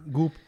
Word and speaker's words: Goop: Goop. – It rhymes with poop.